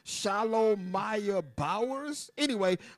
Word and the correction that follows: Shalomia: It's actually pronounced Shalomia. That's a Hebrew name.